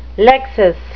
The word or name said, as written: lexis